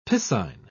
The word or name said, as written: piscine